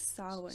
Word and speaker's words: Samhain: And I know that they tried to hold their own festivals, because the how do we say that? Samhain? – I think it's Samhain. – Samhain.